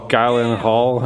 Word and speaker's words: Gyllenhaal: Jarhead with Jamie Foxx and Jake Gyllenhaal. How do you say his name? – Gyllenhaal.